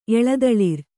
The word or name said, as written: ಎಳೆದಳಿರ್